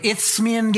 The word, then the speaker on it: Isthmian: I can't even pronounce that. That's really, S-T-H is a weird sound together. Okay, Isthmian Games.